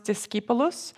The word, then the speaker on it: discipulus: The term disciple originates from the Latin word, it's on the screen. It's pronounced discipulus, and it means a learner or follower.